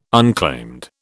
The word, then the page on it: unclaimed: British English